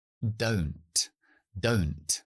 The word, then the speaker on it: don't: El verbo auxiliar do, cuando lo negamos y añadimos el not contraído, en lugar de ser don't, que sería lo lógico, se convierte en oh, don't, como si fuera la sílaba abierta. Don't, don't.